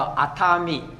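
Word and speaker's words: 熱海: 啊，热海啊，那发音叫做阿塔米，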